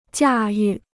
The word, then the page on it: 驾驭: jià yù